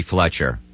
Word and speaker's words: Fletcher: This is Bobby Fletcher. – Hold on, honey. Say your last name. – F-L-E-T-C-H-E-R.